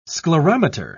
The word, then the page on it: sclerometer: British English